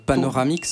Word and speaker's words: Panoramix: Paronamix ? – Ouais, c'est... Oh, il y a une petite inversion de syllabe, mais peut-être que va venir à... – Panoramix.